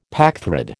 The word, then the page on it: packthread: American English